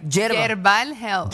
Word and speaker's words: Yerbal Health: Yerba Health, ¿verdad? Eh, ¿Cómo es? – Yerbal Health. – Yerbal Health. Se escribe con Y, es como decir herbal, pero se escribe yerbal.